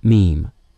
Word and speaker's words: meme: Having first considered, then rejected, the word mimeme, M-I-M-E-M-E, he wrote, "Mimeme comes from a suitable Greek root, but I want a monosyllable that sounds a bit like gene. I hope my classicist friends will forgive me if I abbreviate mimeme to meme." The suitable Greek root was M-I-M, meaning mime or mimic. The English suffix E-M-E indicates a distinctive unit of language structure, as in grapheme, lexeme, and phoneme.